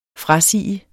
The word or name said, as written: frasige